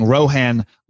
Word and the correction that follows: Rowan: last week. Apparently, the correct pronunciation is Rowan.